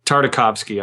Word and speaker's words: Tartakovsky: Yeah, those are the Tartovsky. Tartakovsky. I've been pronouncing his name wrong the whole episode.